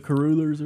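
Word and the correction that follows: cruller: Kruller. It's Kruller. – I mean, oh, is it Kruller? – Yeah, it's Kruller.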